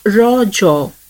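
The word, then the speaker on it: Raja: The festival is natively pronounced as raw jaw, a local term that means menstruating woman.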